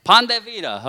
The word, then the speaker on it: Pan de vida: But this is called Vida. Did I say that right? Pan de vida.